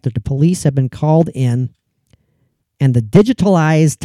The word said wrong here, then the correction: digitalis: type medication, digitalis type, butchering that pronunciation or pronunciation. Sorry if I am. – Digitalis? – Digitalis type medication that had possibly been used for the alleged killings had begun to be kept under lock and key.